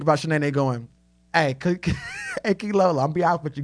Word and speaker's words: Keylolo: Okay, y'all ever seen Martin whenever they win the car and Key Lolo's in the back and Tommy, he says her name wrong and she goes, it's Key Lolo. K-E-Y-L-O-L-O.